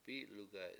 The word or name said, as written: p7I7lugay7